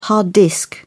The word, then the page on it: hard disk: British English